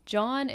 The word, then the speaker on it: jawn: John, it's spelled J-A-W-N, and it's basically anything at all.